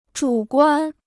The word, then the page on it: 主观: zhǔ guān